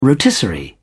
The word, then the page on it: rotisserie: British English